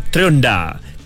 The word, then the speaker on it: Trionda: Maksud Trionda, okay, T-R-I, okay, ejaan dia T-R-I-O-N-D-A, sebutan dia Trionda, Trionda, okay.